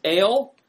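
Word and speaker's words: Ale (אֵל): So Strong's number 410, the singular word El, E-L. Sometimes it's referred to as Ale, is how it's pronounced. A-L-E is how you might pronounce it.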